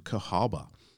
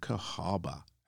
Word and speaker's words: Cahawba: The second place that I'm going to tell you about that you can visit is Old Cahaba, and it's spelled C-A-H-A-W-B-A, Old Cahaba, Alabama.